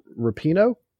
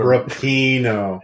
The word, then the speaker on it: Rapinoe: I do really want to say, because it's been bugging me, and I'm a huge dick for saying this, but her last name is pronounced Rapinoe. – I looked that up, and I was hoping I could keep pronouncing it wrong. – But Rapinoe.